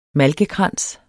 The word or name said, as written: malkekrans